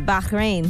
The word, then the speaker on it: Bahrain: Bahrain.